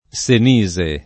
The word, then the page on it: Senise: lingua italiana